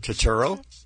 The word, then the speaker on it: Turturro: How would you say his name? – Turturro? – Yeah, but I feel like I'm slurring it.